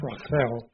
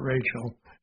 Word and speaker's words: Rachel: And it would be pronounced Rachel in Hebrew and not Rachel as we would pronounce it.